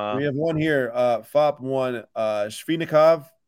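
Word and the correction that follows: Svechnikov: I think I pronounced it right. Or no. Svezhnikov. Svezhnikov. I used to have 99.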